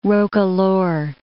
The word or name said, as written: roquelaure